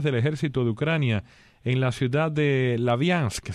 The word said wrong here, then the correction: Sloviansk: ¿Se pronuncia así, Natalia? – Sloviansk.